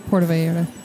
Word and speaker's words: Vallarta: Puerto Vallarta. – You sure? – Pretty sure. – When it's 2 double L's, it's pronounced like a Y of yellow.